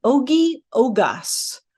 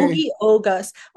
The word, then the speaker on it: Ogi Ogas: His name is Ogi Ogas. – Okay. – Ogi Ogas.